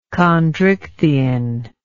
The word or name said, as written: chondrichthian